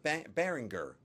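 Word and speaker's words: Baringer: Is it Behringer or Behringer? – Oh, sorry. It's a hard G. – That's what I thought. – Ringer. – Yeah, Behringer.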